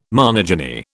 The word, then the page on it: monogyny: British English